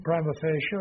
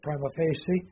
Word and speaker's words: prima facie: So, using the Latin prima facie or prima facie, they pronounce it both ways, evidence is what?